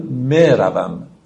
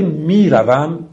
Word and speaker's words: میروم: یا مجهول یعنی یایی که به شکل کسره کشیده تلفظ میشه. مثلا اگر فیلم افغانی یا تاجیکی گوش داده باشید یا موسیقی افغانی یا تاجیکی، میبینید که میگن مثلا مروم. دقت میکنید اون ای که میکشن اون رو بهش میگن یا مجهول، ولی میروم که ما ایرانی ها تلفظ میکنیم این یا معروفه.